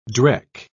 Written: dreck

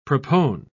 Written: propone

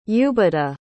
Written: ubadah

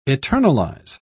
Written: eternalize

